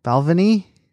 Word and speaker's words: Balvenie: It's like Benvenue or something like that. I'm fucking butchering it. Double cask. – Balvenie? – What did I say?